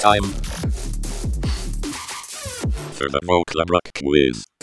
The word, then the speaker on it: Vöcklabruck: Um, pr- pronunciation-wise, you're born and brought up in Volkerbruck. Vöcklabruck. Vöcklabruck, yeah. Vöcklabruck, yeah. Th- thank you.